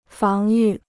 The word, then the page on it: 防御: fáng yù